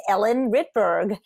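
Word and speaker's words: Ellen Rittberg: My website is Ellen Ritberg, and my last name is spelled R as in Ralph, I-T-T, B as in boy, E as in elephant, R, G as in George, first name Ellen.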